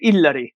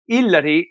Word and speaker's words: Illari: And I said, that kind of works, because in Italian you don't have Y as a letter in the alphabet. So Illari is I-double-L, that's how it's pronounced.